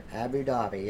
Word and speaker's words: Abu Dhabi: Abu Dhabi. – Have you ever seen Abu Dhabi? – Yeah, you say you're saying that right. – Yeah. Okay. Abu Dhabi.